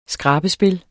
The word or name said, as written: skrabespil